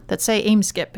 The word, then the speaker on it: Eimskip: People who are driving on the Casco Bay Bridge who look down towards the water on the Portland side, they see big containers that now say Eimskip. It's E-I-M-S-K-I-P. – Absolutely. And you pronounced it right.